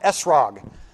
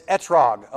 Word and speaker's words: etrog: If you're from the Northeast, it's pronounced esrog, but it's etrog.